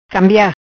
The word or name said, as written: Cambia